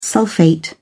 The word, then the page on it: sulphate: British English